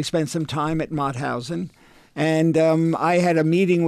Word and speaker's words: Mauthausen: Mauthausen. – Mauthausen concentration camp.